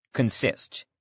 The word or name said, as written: consist